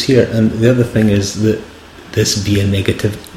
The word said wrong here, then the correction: via negativa: I'm going to say it wrong, via negativa. I think you need a sort of slightly less Presbyterian accent to do that one, via negativa.